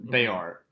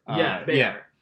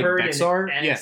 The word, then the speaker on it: Bexar: Well, even so, the town, Bayar. – Bayar. Say it right. – But you can't fault somebody if they're like Bexar. – Make a Y sound.